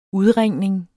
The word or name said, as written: udringning